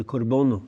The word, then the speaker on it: korbono: In fact, in the Maronite church, we talk about the liturgy as the korbono, which is the Syriac pronunciation of korban, meaning bringing near, that's its root, but it also means offering to God, and that's how it's used.